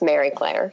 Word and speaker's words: Marie-Claire: Well, my parents did me a service by spelling my name Marie Claire, and it's Mary Claire, so.